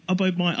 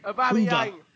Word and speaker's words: Aubameyang: He's saying Arsenal close to agreeing fee of around 16 million with Borussia Dortmund for Pierre-Emerick Aubameyang. I can't pronounce his name. – Aubameyang.